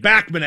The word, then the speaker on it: Bachman: Bachman, actually. That's how he pronounces it, in Cleveland.